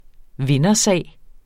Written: vindersag

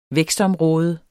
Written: vækstområde

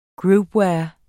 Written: groupware

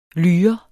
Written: lyre

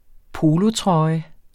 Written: polotrøje

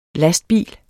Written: lastbilers

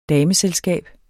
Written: dameselskab

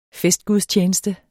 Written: festgudstjeneste

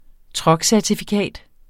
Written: truckcertifikat

